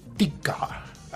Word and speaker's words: Tykwer: Se escribe T-Y-K-W-E-R. Tykwer se pronuncia Tika, algo así en alemán.